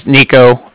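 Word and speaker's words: Niko: This is Nico. That's N-I-K-O, calling from the Bank of Cardiff, and that's spelled C-A-R-D-I-F-F, as in Frank Frank.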